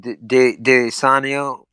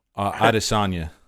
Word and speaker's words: Adesanya: How do you say his name? Adesanya. – Adesanya. – Adesanya. – Yeah.